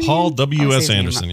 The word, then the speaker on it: Paul W.S. Anderson: Paul W.S. Anderson.